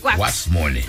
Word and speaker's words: huaxmole: Se escribe H-U-A-X-M-O-L-E. – Ok, guasmole. – Pero le dicen guas, con G, guas.